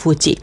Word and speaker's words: ฟูจิ: ฟูจิฟูจิโนฮานะนะคะฟูจิเนี่ยจริงๆแล้วออกเสียงสั้น